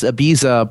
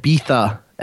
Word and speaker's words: Ibiza: That said, my favorite part of this song is the hotly contested way to pronounce Ibiza, because you would think it's just Ibiza, but the British like to pronounce it Ibiza. And so if you're feeling extra pretentious, and it has something to do with the way the Z is pronounced in Spanish.